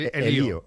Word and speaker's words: Elío: Pero lo correcto sería General Elío, con acento en la I.